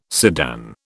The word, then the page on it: sedan: British English